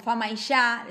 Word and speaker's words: Famaillá: Bien, nosotros decimos, igual aprovecho para decir esto, que nosotros la doble L o L y la Y nosotros van a ver que muchas veces la vamos a, pronunciar como sh, ¿sí? Entonces decimos fama y ya, decimos yo, es un como, eh, son palabras que quizás si nosotros decimos fama y ya, ¿no?